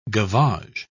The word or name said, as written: gavage